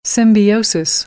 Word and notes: symbiosis: American English; oʊ

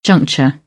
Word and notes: juncture: British English

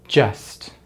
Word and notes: just: American English

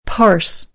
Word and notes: parse: American English

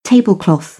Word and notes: tablecloth: British English

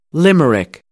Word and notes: limerick: American English; ɚ

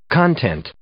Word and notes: content: noun

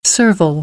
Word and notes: servile: American English; aɪ

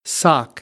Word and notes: sock: American English; ɑː

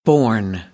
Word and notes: borne: American English; ɔːr

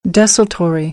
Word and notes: desultory: American English; ɔː - ə

